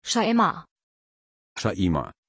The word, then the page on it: Shaima: German